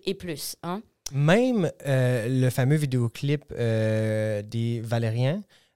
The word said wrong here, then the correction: Valérian: je le prononce très mal, je m'excuse. – De Valérian. De Valérian.